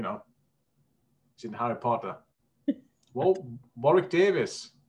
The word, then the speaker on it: Warwick: Warwick's getting it. I think it's called Warwick, isn't it? That's how we pronounce it.